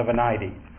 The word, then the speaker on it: Venite: The Latin name of the psalm is Venite Exultimus, or as we say in Anglicanism, the Venite. This English Latin pronunciation is very strange.